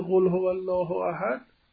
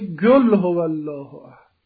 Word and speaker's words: قل: ترکه وقتی میرسد به قل هو الله احد میگه گل هو الله احد.